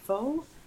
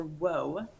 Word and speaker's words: Whau: It's spelled W-H-A-U, and it's pronounced Faux or Whoa.